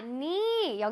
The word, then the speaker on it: knee: 자, 니 여기 무릎을 할때 무니는요 K가 여기서 소리가 나지 않습니다. Silent예요. 그래서 K 소리가 큰 소리가 나지 않고 그냥 니라고 읽어요.